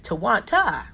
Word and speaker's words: Twonta: and my name is a T-W-O-N-T-A, it's Tawanta, right? T-W-O-N-T-A, no Tawanda, no Tawana, no, not no Tuowanda, nothing like that, it's Tawanta, right?